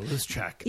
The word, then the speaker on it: Luschek: Oh, Luschek.